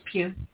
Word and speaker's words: Pugh: Florence Pugh, or Pug, or however you say her name. – Just Pugh. – Pugh, that's what I thought.